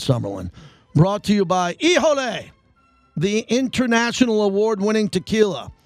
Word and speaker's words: Hijole: Ijole, spelled H-I-J-O-L-E, pronounced Ijole, where you can get it throughout town.